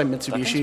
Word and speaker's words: Mitsubishi: You just pronounce every individual like consonant vowel syllable in Japanese. – Kurigane? – Yeah, Kurigane. Yep. – Fine. – That's why Mitsubishi is Mitsubishi, not Mitsubishi.